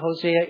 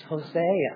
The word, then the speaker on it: Hosea: And you say, well, the first thing I learned about Hosea is sure pronounces it funny. It's Hosea. Either pronunciation's fine. I do Hosea.